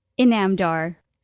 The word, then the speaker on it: Inamdar: Let me pronounce your last name properly. I will repeat after you. – Inamdar.